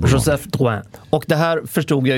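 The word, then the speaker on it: Drouhin: Drouin, tror jag. – Drouin. Inget P. Drouin.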